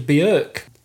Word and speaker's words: Björk: So that refers to Trevor Moraes, who's a session drummer for Tina Turner, David Essex, Howard Jones and Björk. – Good pronunciation of Björk, though, by the way. – Thank you. – That is how you're supposed to say it, I think.